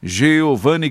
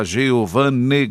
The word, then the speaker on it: Geovane: O canal do Gandra no YouTube, Geovane Gandra. Só digitar lá, Geovane Gandra, vai aparecer o canal do YouTube. Meu nome se escreve com E, viu, gente? G-E-O-V-A-N-E-G-A-N-D. Aí vem o R-R-A, tá bom? É Gandra, tá?